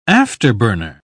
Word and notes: afterburner: British English

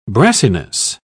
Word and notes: brassiness: British English

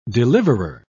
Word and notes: deliverer: British English

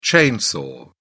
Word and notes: chainsaw: British English

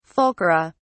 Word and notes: fulcra: British English

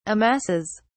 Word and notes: amasses: British English

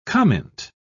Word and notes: comint: British English

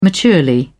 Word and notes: maturely: British English

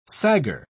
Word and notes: saggar: British English

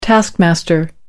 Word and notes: taskmaster: British English

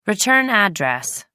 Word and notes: return address: American English

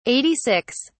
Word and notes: 86: British English

